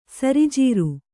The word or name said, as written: ಸರಿಜೀರು